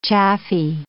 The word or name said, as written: chaffy